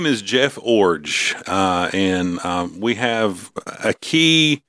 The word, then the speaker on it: Iorg: Orge like a forge. He told us this like when we met with him. – I-O-R-G. – I-O-R-G, but you say it like forge without the F, so Orge.